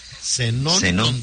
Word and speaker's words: Zenón: Zenón con Z y acento en la O.